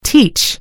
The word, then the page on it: teach: American English